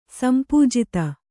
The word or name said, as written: ಸಂಪೂಜಿತೆ